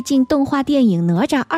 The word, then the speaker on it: 哪吒: 哪吒。 – 您再说一下那名字。 – 哪吒。 – 这是台湾的发音对吗？ – 诶，我就是这样念错了吗？ – 哪吒。 – 哪吒 OK 好。 – 你知道有一些方言叫拿抓，都有各种发音都有，反正很好玩。